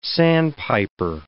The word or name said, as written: sandpiper